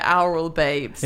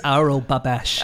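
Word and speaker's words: Aurel Babeș: How do you actually pronounce it? – All right, fine. I think you probably say it, Oral Babes. – It's Aural Babesh.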